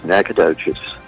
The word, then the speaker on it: Nacogdoches: I think he said Nacogdoches. Is that how you say it? Nacogdoches? – Yes, Nacogdoches.